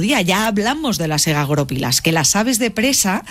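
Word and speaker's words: egagrópilas: Egagoropilas. – Vamos a ponerlo, o sea, el acento en la O.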